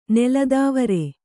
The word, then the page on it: ನೆಲತಾವರೆ: nela tāvare